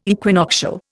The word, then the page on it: equinoctial: Indian